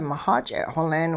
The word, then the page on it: mixach'e'-xoleen: hupa